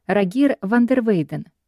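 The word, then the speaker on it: Rogier van der Weyden: Рогьер ван дер Вейден. Нидерландское произношение имени валлонского художника Роже де ля Патюра.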